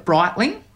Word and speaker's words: Breitling: Is the right way to pronounce the watch, is it Breitling? – Yes. – Right. So talk me through photo shoots. So you've got some amazing photos with you, Steph, and Kelly looking cool with your Breitling watches on.